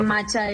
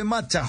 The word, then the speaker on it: matcha: El, macha es esa opción. Se escribe matcha, José Puentes. Eh, se escribe matcha. Mat-cha, con che.